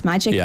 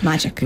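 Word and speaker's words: MAGEC: It is pronounced magic, but it's magnetic controllable rod. – Right. – So it's just magic.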